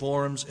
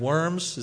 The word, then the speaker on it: Worms: It is called the Diet of Worms, spelled W-O-R-M-S, so do with that as you will. The Diet of... So, pronouncing the W as a German is important, okay? The Diet of Worms is not as good as the Diet of Worms, okay?